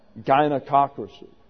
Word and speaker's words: gynecocracy: And so, Oxford English Dictionary gives it that ZG, gynecocracy. You see the British saying that, gynecocracy.